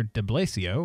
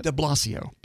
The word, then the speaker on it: De Blasio: de Blasio, or whatever, his name's spelled weird, How do you say it? – De Blasio. – De Blasio. Man, I knew I was going to butcher it.